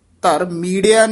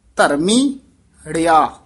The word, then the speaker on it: धर्मी हड़िया: ये पाठ ध्यान नाल उच्चारण करना है, बोल इथे यमक ला देनी है, सो पाठ वखरा उच्चारण करना है, संप्रदा च वखरा पाठ कराया जांदा है। सो धर्मीड़िया नहीं कहना है, धर्मी हड़िया यानी डैश जेड़ी है ना,